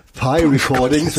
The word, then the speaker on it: P Recordings: Ich habe mich eben versucht zu recherchieren, wie das P Recordings auf Englisch ausgesprochen wird. Und es ist tatsächlich so, wie der, wie heißt das, der Kuchen? – Der Kuchen. – Also es ist Pi Recordings.